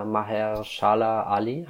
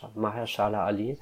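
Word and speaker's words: Mahershala Ali: Maher, Maher Shala Ali, habe ich es richtig gesagt? – Ja. – Maher, Maher Shala Ali, richtig toller Schauspieler.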